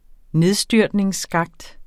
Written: nedstyrtningsskakt

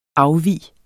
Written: afvig

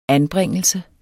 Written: anbringelse

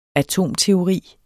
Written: atomteori

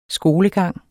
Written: skolegang

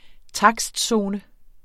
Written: takstzone

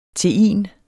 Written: tein

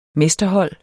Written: mesterhold

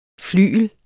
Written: FLYGLER

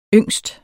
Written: yngst

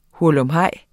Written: HURLUMHEJS

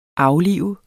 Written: aflive

